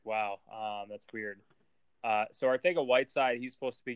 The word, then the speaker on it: Arcega: Did you know that that's how you pronounce it? It's Arthega, not Arcega.